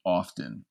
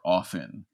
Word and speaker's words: often: My sister complained that I pronounced the T in the word often, or often, I guess, is how you're meant to say it.